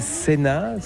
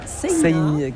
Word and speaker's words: Seina: Sena, Seina. En fait, ça s'écrit un peu comme la Seine, mais... – Voilà, avec un A à la fin, Seina. On prononce le I aussi.